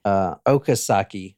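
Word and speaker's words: Okazaki: And then Dale Osaki is upstairs. And that is not how you say her name. I'm sorry. Okasaki is upstairs.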